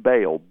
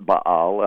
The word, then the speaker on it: Baal: The term Baal, B-A-A-L, now that's how we usually say it in the English. In Hebrew, it would be most correctly pronounced Baal, with kind of a guttural stop, but none of us speak that way, so we don't call it Baal, and I'll just call it Baal because that's what everybody calls it. – Mm-hmm. – But in the English, anyway, B-A-A-L.